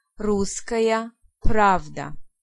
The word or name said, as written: Pravda